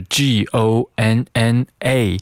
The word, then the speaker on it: gonna: 那在这句话中，我们看到了一个缩写的形式，它的拼写是 G O N N A，读作 gonna。